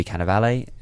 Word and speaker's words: Cannavale: You've got Louis C.K., unfortunately didn't win an Emmy, poor guy, but he's fantastic in this, and Bobby Cannavale, is how you pronounce his name, he's fantastic too.